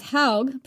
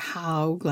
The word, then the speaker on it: haug: Calling the mound How would make perfect sense, as haug, pronounced haug, like smaug, is Old Norse for a mound. – To me, it looks more like haug, like a hog, but you know.